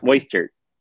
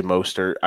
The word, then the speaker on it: Mostert: Moistert. – Moistert. – I can't do it. Can't do it. Moistert. – I'm going to say Mostert. I think McKinnon, it's too hit or miss, and Mostert has shown the ability, plus on a team that actually cares about running the ball.